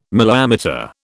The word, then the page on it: milliammeter: British English